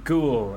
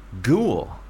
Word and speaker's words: Goole: I'm from Goole in East Yorkshire. – This is now you're just making stuff up. Where Ghoul? – Yeah. – How do you spell that? – G-O-O-L-E. – Ghoul? – Yeah. – G-O-O-L-E.